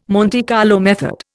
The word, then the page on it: Monte Carlo method: Indian